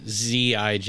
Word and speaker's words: zijn: The IJ in Dutch is pronounced I. So, Zijn is Zijn.